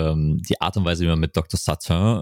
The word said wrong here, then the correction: Sartain: ähm, Satane, ich glaube, Sartain ja.